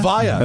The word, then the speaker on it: Evija: Is that how you pronounce that? Evija? – Evija. Okay. Yeah. I'm glad someone was here to tell me.